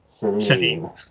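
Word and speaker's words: Chailene: But I spell it C-H instead of S-H because it's like a French derivative. So it's very like Shailene.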